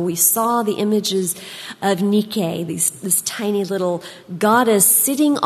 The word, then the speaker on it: Nike: It's the word Nike, or if you're going to pronounce it correctly in Greek, it's Nike.